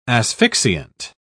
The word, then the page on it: asphyxiant: British English